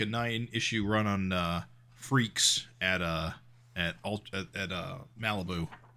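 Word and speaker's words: Freex: I guess I didn't realize it's pronounced Freaks.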